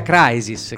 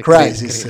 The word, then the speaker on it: Crisis: Credo sia Crisis. – Crisis, sì, scusate.